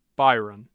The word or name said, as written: byron